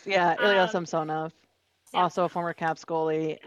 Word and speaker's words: Samsonov: Ilya Samonov, Samsonov, also former Capital goalie. – You are butchering the former Capital goalie's last name.